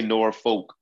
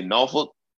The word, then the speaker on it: Norfolk: We don't say Norfolk. That lets me know you from Massachusetts, or that lets me know you from Connecticut, or, you know, Iowa. Everybody, if you from Virginia, you say Norfolk, you say Norfolk. I think that's the only appropriate way to pronounce this shit.